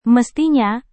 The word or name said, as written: mestinya